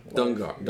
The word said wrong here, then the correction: Dengar: Den, Dengar.